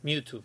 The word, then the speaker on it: Mewtwo: Mewtwo, vale. – Mewtwo, ¿no? Yo le llamo Mewtwo.